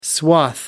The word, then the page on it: swath: British English